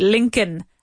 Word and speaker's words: Lincoln: It's spelled with an L. Lincoln. Empieza por una L, pero también tiene una L metida. Lincoln. That's how it's spelled, but we don't say that L. We say Lincoln.